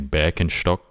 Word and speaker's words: Birkenstock: Well, you know the German way of saying it is actually Birkenstock. – Birkenstock. Okay. Yes. That's good to know when I go to Germany. When I, yeah, nice Birkenstocks, friend. – Yeah, man, that's the right way to say it.